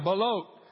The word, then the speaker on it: balut: I don't know how you say it. Balut. Yeah, balut.